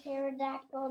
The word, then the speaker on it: pterodactyl: Why did you hear a pterodactyl in the bathroom? – I don't know why. – Because P is silent.